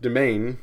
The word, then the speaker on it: demesne: Pronounced domain, spelled D-E-M-E-S-N-E. Gotta love French.